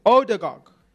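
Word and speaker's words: Ødegaard: I hope I say his name right. Oligarch. No, I'm saying his name so wrong. I'm butchering his name. I am so sorry. Odegaard.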